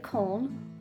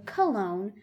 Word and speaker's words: Cologne: Now you can pronounce this the German way, Cologne, or the English way, Cologne.